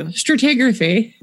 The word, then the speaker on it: stratigraphy: So we're getting back to basics with an episode on stratigraphy. Did I get it? – I'm uncomfortable with how you pronounced it. Just pronounce it how you usually do. – Stratigraphy. – Perfect.